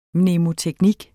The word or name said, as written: mnemoteknik